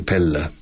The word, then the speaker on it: Pella: Doble L, pero pronunciado L, simple Pella.